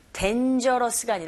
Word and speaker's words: dangerous: 이 부분에 강조해 주시고 dangerous가 아니라 dangerous. Dain, 감으세요.